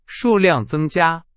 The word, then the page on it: 数量: Chinese